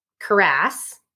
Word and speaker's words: cuirass: And the armorer forges him a full cuirass, that's how you say it, from most of the Beskar steel, while the remainder goes to support Mandalorian foundling children.